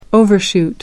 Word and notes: overshoot: American English

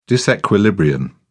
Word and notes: disequilibrium: British English

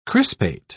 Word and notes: crispate: British English

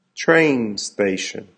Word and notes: train station: American English

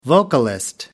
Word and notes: vocalist: American English

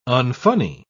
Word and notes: unfunny: British English

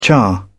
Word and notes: char: British English

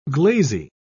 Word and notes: glazy: British English

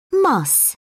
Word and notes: muss: British English

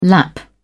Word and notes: lap: British English

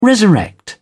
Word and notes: resurrect: British English

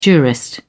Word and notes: jurist: British English

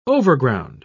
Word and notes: overground: British English

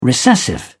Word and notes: recessive: British English

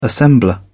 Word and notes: assembler: British English

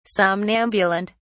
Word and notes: somnambulant: British English

